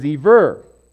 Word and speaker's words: Eber: There would be a dot right there if you were to say Eber. So, the pronunciation is Eber.